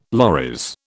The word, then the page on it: loris: British English